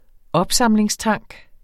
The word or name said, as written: opsamlingstank